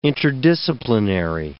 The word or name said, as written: interdisciplinary